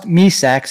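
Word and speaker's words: Mysak: Pronounced Misak, spelled my sack.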